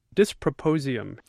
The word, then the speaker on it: dysprosium: It's properly pronounced dysprosium, D-Y-S-P-R-O-S-I-U-M.